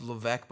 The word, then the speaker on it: Levesque: And it's pronounced Levesque, but it's spelled L-E-V-E-S-Q-U-E.